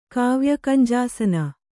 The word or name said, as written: ಕಾವ್ಯಕಂಜಾಸನ